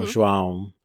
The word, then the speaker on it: João: It's really nasal. – Okay. – And when in doubt, pinch your nose. So, ow, cão, João.